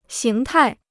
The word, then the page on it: 形态: xíng tài